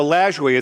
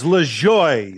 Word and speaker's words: Lajoie: Now, it's Lajoie. – It isn't Lajoie. It's Lajoie. – No, he doesn't know what the hell he was talking about, Lajoie. – How do you pronounce it? Come on, help me. – Don't. – It's Lajoie. – Lajoie. – Lajoie, L-A-J-O-I-E. – He pronounced it Lajoie.